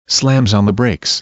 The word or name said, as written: slams on brakes